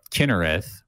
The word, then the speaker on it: Kinnereth: which is, or a Kinnereth, really. The sound is like kind of somewhere between the two if you try to pronounce it with a, you know, like a Hebrew accent.